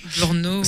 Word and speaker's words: Brno: Brno, c'est... – Brno. – Ouais, c'est Bruno sans le U.